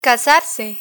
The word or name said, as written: casarse